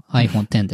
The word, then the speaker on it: iPhone X: うん、で、それがその今回の Apple TV 4K であるとか、後で話す iPhone X と書いて 10 と読む iPhone X ですね。